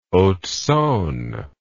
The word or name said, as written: haute saone